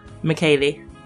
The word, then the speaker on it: Makele: Wait, how do you say your last name? – Makele. – Makele. – Yes.